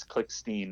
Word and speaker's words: Klickstein: It is a little fun to confuse people, but it is spelled with one T, and it is Klickstein, not Klickstein.